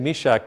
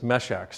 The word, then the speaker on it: Meshach: That's actually, I keep saying Meshach, but I've just realized that the pronunciation is Meshach.